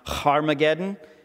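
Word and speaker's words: Harmagedon: As a little side note, we, a few weeks back, we talked about Armageddon and how in the Greek it's actually pronounced Harmageddon and that it's the mount of something.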